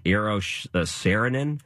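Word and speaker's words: Eero Saarinen: Eros Saarinen.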